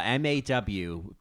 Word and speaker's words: maw: A maw. M A W. Pronounced maw.